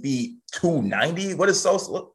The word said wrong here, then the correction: D'Souza: Don't pronounce his name as if he's like a new nickname for Chief Keef. Okay, it's Silvio D'Souza.